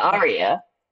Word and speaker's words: Aria: Yeah, Aria.